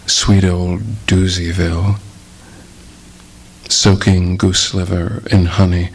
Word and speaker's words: Dewseavile: But it's spelled in many ways, and the way this title is spelled is D-E-W-S-E-A-V-I-L-E, Doozyville.